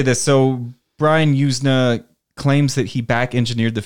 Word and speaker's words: Yuzna: This is from Brian Yuzna. I'm maybe pronouncing his name wrong. Y-U-Z-N-A.